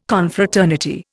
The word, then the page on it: Confraternity: Indian